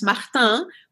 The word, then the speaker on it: Martin: And if you pronounce it the French name, it's Martin, which is as popular as Smith and Jones in France.